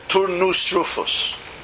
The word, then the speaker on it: Turnus Rufus: The representative of Rome in Judea was a man named Turnus Rufus. Okay? Four syllables. Turnus Rufus.